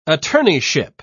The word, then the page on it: attorneyship: British English